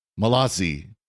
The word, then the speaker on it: Malzahn: Susan Gardner 19 on Twitter says, with all respect, please learn that Joel Malzahn's name is pronounced Malazi.